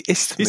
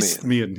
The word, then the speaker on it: Isthmian: Please say it, for me. – What they say, Isthmian. Isthmian, yeah. Isthmian.